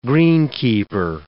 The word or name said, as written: greenkeeper